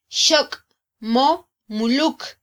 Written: xok mo muluk

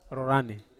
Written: ro rane

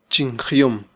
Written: chinjyom

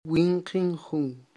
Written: winqin jun

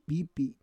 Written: pipi

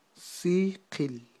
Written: siqil

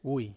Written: wui

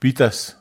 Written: pitas

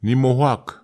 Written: nima awhak

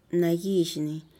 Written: nagizhni

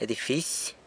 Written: edifisy

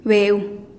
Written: beu

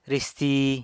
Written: risti

